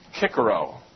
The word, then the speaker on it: Cicero: but you don't pronounce soft C's in Latin. So, for example, this man's name in Latin was Cicero, because there are no soft C's.